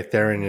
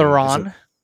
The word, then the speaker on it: Theron: By the way, Theron isn't the correct pronunciation. – Theron.